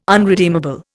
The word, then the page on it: unredeemable: Indian